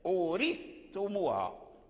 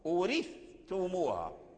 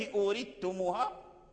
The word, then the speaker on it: أورثتموها: أورثتموها، أورثتموها. أما إذا قلت أورثتموها فلم يظهر الثاء.